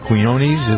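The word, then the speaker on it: Quiñones: Burbridge and percussionist Mark Quinones. Is that how you pronounce his name? – I believe so, yeah.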